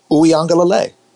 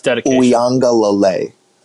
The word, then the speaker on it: Uiagalelei: Is that really how you pronounce it? – Uiagalelei. I'm in broadcast for a living. I had to, no, you're wrong.